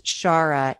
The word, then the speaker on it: Xagħra: it starts with an x actually but it's pronounced Shara and it's near the beach.